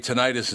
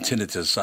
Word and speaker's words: tinnitus: You know, you and I didn't talk about, people say tinnitus and tinnitus. I think it's tinnitus, isn't it? – It's, you know, doctors pronounce it tinnitus. – They do, yes. – Tinnitus is also an acceptable pronunciation.